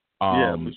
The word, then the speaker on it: Buscemi: And he's played by Steve Buscemi. I think that's Buscemi. – How you pronounce that. Buscemi. Yeah. – Buscemi.